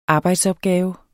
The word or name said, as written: arbejdsopgave